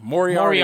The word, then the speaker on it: Moriarty: Bryan Danielson versus Minoru Suzuki and Bobby Fish versus Lee Moriarty, I think is how you pronounce Moriarty. – It. – Moriarty. Moriarty. You like the Sherlock Holmes villain. – Oh, Moriarty. Yeah.